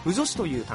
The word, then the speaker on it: 腐女子: 突然ですが皆さん、腐女子という単語、ご存知でしょうか。